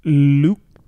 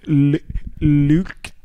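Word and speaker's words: Lütke: Lütk. – Spell it. You did the sentence. – CEO of Shopify. Origins. German guy in Canada. – Can you say that again? – Lütk.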